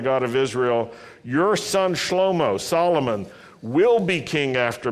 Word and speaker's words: Shlomo: How do you say Solomon in Hebrew? Shlomo. That's right.